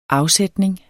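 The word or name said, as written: AFSÆTNING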